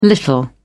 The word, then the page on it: little: British English